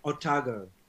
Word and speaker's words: Otago: It's pronounced Otago.